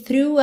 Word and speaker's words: through: Through. Atenção que esse som é bem fechadinho. O GH do final não é pronunciado. E o começo, f-f-f, linguinha lá nos dentes da frente, tá?